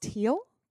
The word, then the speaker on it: Thiel: I mean, this is great news, but also like, one of PayPal's founders is Peter Thiel. – Oh, yeah. Is it pronounced Thiel? – Thiel, yeah. – I've been saying Thiel forever. – Pretty sure it's just Thiel.